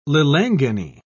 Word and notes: lilangeni: British English

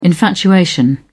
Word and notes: infatuation: British English

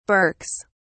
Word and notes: berks: British English

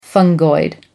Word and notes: fungoid: American English

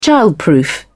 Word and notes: childproof: British English

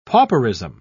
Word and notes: pauperism: British English

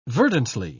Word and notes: verdantly: British English

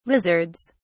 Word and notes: lizards: British English

